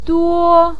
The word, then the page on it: duo: 1